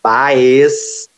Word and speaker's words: Baez: então, eu sou Arizona Baez, B-A-E-Z, em todas as redes sociais, mas se escreve, mas se lê beis, por favor, não erre meu nome.